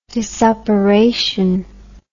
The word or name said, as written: disoperation